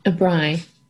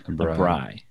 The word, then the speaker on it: braai: A braai. – A braai. – Yeah. Okay. It's spelled B-R-A-A-I.